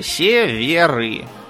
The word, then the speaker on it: Северы: Внимание, ударение правильное. Северы.